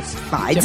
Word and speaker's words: Pfizer: Pfizer, ecco, e io l'ho detto in maniera anche perché, no, secondo la lingua italiana, quando tu non sei tanto in grado di pronunciare il termine nelle altre lingue, puoi avvalerti della facoltà di pronunciarla così come è scritto, quindi è Pfizer.